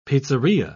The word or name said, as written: pizzeria